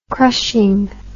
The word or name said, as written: crushing